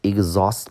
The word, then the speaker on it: exhaust: 下个单词 exhaust，exhaust，注意 h 不发音，x 发个 z。比如说考试叫做 exam，一个 z，一个 z。那么 h 不发音就翻译成 exhaust，exhaust。它的意思叫使某人精疲力尽。比如说这件事情让我很累，叫做 it exhausts me，exhaust me。